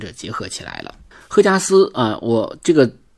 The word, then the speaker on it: 荷加斯: 名字呢叫荷花的荷啊，这个也也在字呃就是发音中也读第四声嘛，所以我就习惯性的读它赫加斯，因为它还有一个名艺名，就是用咱们中国这个姓贺的贺，就是祝贺的贺啊，叫赫加斯，但是我还是用这个荷花的荷啊，但是我读第四声。那么还有一个发音叫霍加斯啊，就是霍元甲的霍。